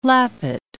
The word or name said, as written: lappet